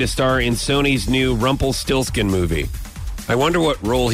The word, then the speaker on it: Rumpelstiltskin: I don't remember the whole storyline of Rumpelstiltskin.